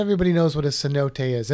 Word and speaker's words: cenote: And if you see the word, you may not pronounce it right, because it's spelled C-E-N-O-T-E-S. – It's like cenotes. – Cenotes? – Cenotes? – Is it key? Is it canotes? I don't know. It's cenote. All right? It's a cenote.